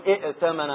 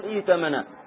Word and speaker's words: ائتمن: هنا الهمزة نبتدأ بها مكسورة لأن الحرف الثالث مفتوح، نبتدأ بالهمزة مكسورة نقول ائتمن، كنا نتحول إلى إياء في النطق، ما نقول ائتمن هكذا بهمزتين، نقول ائتمن مثل إيمان وهكذا ائتمن، لكن تكتب هكذا.